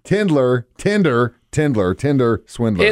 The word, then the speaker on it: Tinder Swindler: 104.7 The Cave back in the studio one more time discussing the Tindler Swindler. – Tinder Swindler. – Before, I keep saying it wrong. Tinder Swindler.